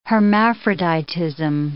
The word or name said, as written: hermaphroditism